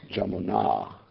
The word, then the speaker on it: Jamuna: Now, generally, long A denotes female, and short A, just like Krishna, and Krishna, Draupadi. So here, Jamuna, because river's streaming.